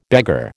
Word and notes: beggar: American English

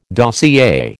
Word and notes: dossier: American English

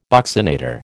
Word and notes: buccinator: American English

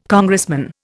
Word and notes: Congressman: Indian

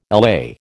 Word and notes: LA: American English

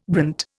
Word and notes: brant: Indian

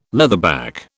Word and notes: leatherback: British English

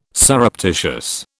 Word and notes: surreptitious: British English

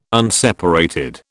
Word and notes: unseparated: British English

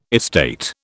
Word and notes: estate: British English

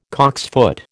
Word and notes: cocksfoot: American English

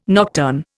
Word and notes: nocturn: Indian

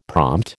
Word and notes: prompt: American English

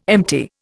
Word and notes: empty: Indian

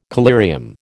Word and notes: collyrium: American English

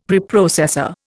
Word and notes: Preprocessor: Indian

